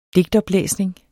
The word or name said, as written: digtoplæsning